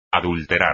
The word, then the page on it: adulterar: espagnol